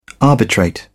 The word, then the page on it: arbitrate: British English